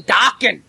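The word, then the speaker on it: Daken: Apparently, he's European, where A is pronounced Ah, Dakin. – Only people could see you when you say that. – Dakin. – Nine Dokken.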